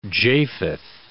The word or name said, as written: Japheth